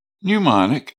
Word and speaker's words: mnemonic: Each letter of the word time, spelled T-I-M-E, will represent a word. The word mnemonic is spelled M-N-E-M-O-N-I-C and is pronounced mnemonic. The pronunciation of mnemonic starts with a N sound, even though the spelling starts with a M. The word mnemonic is derived from the Greek word pneumon, spelled M-N-E-M-O-N, which means mindful.